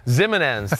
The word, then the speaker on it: Ximenes: The kid they got from Old Dominion, O'Shane, I can't even say his name, Ximenes or whatever. Ximenes, thank you very much.